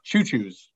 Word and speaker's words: Cucuz: Pronounced choo-choos, like a train.